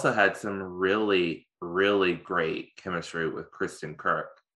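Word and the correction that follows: Kreuk: Kristen Crook. Yeah, say that 5 times fast. – Kristen Crook.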